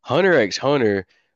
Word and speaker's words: Hunter x Hunter: But Hunter x Hunter, like you're talking about motherfucking Luffy looking annoying. I don't like anything I'm seeing from Hunter. You don't pronounce the X it's part of their titling.